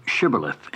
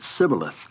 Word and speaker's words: shibboleth: Then said now unto him, say now shibboleth. And he said sibboleth, for he could not frame to pronounce it right.